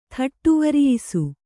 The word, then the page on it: ಥಟ್ಟುವರಿಯಿಸು: thaṭṭuvariyisu